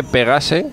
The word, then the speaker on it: Pegase: Eh, se escribe Pegase. – Pegas, Pegas. – Pegas. Pegas pero ellos son franceses.